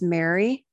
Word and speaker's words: Mary: It's Mary, M-A-R-Y, Joy, J-O-Y-E.